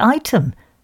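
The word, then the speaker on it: Ightham: My favourite, though, not rude, is Trottiscliff, pronounced Trosley, with nearby Rotham, W-R-O-T-H, pronounced Rootum, and Igatham, pronounced Item.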